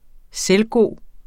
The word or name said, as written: selvgod